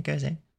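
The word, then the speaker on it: Gose: It's known as a goze, right? Or how do you even pronounce that? – That is a style. Yeah, goze.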